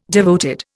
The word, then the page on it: devoted: Indian